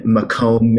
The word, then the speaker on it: Macomb: So returning this week, we have two leaders from the interim healthcare location in Macomb, Michigan. I said that right? – Yeah. You did.